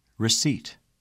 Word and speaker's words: receipt: The letter P is silent in the following words.Repeat them during the pauses. – Receipt.